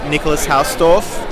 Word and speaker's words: Nicolas Hausdorf: And I'm here with Nicolas Hausdorf.